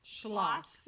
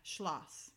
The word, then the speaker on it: Schloss: Schloss. – Like schlong and then loss. – Yeah, Schloss. Anytime you have German word and it's E-I, it's pronounced I, and if it's I-E, it's pronounced E.